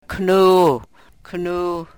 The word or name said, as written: knuuu